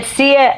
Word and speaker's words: Sia: Did I pronounce that right? – Sia, I think yes.